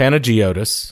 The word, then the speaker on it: Panagiotis: Pangiotis. Pangiotis Mistriotis. – No, Panagiotis.